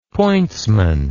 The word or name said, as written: pointsman